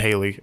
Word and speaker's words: Halle: I think it's pronounced Haley, actually.